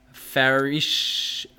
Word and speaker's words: pharisaios: The word Pharisee in Greek is pronounced pharisaios, and was derived from the Hebrew word pronounced parash, which literally means to separate. Thus, the word Pharisee literally means separatist.